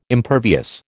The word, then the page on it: impervious: American English